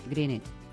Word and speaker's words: Greenwich: ¿Y cómo mola eso de decir Greenwich? Yo me acuerdo cuando era pequeña. Aquí me hizo aprender el meridiano de Greenwich. – Greenwich. – ¿Cómo se dice? – Greenwich. – Madre mía, qué bien.